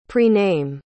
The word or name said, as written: prename